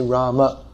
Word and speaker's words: Rama: So to say the name properly according to Sanskrit pronunciation, we should say Rama.